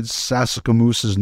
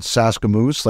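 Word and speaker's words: Sasakamoose: I'll say this, at the outdoor game in Regina, which I thought was a phenomenal outdoor game between Winnipeg and Calgary, I said Fred Sasikamoose's name wrong twice. Mm. And I called him Fred Saskamoose, like just in excitement.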